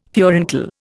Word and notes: furuncle: Indian

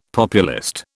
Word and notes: populist: British English

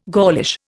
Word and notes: Gaulish: Indian

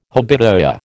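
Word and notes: habitué: American English

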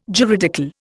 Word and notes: juridical: Indian

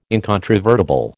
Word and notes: incontrovertible: American English

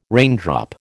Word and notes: raindrop: American English

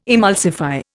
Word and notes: emulsify: Indian

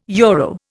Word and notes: euro: Indian

